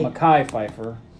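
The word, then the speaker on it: Mekhi Phifer: Andre is played by Mikey Pfeiffer. – It's Mackay. – Mackay Pfeiffer.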